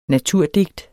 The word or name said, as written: naturdigt